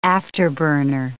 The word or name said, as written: afterburner